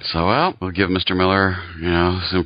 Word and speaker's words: Millar: Clint Magazine is now going to be labeled, at least on the magazine, Mark Millar's Clint Magazine. I guess Mark. – His name is pronounced Mark Millar.